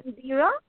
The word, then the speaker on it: Indira: My name is Indira. Did you get my name? Okay, I'm sorry. Can you spell that for me, please? – I-N-D-I-R-A, Indira.